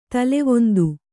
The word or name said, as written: ತಲೆವೊಂದು